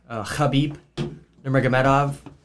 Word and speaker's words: Khabib Nurmagomedov: So it was maybe the best pound-for-pound fighter ever, Khabib Nurmagomedov versus Justin Gaethje. – Kudos on the pronunciation.